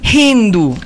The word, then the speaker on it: Hindu: Mi nombre es Hindu. – Hindu. – Sí. Hindu, sin acento en la U, así como J.